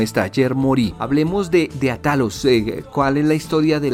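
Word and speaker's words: Atalhos: Claro, Atalos más que nada significa atajos en castellano, lo único es que se escribe con LHOS, pero significa atajos.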